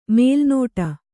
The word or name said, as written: ಮಲೆನಾಡು